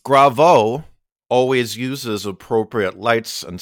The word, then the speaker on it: Graveaux: Gravo is spelled G-R-A-V-E-A-U-X. So French.